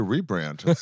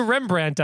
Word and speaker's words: rebrand: I just think you need a rebrand. Start it over. – How would you rebrand us? – First of all, that's a wild pronunciation of rebrand. – Rebrand? – How would you Rembrandt us? – Yeah.